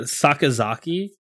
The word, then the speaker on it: Sakazaki: Sakazaki. – Sakazaki.